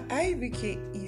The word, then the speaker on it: IBK: call me IBK or IBK. My IBK is spelled this way, IBEEKAY.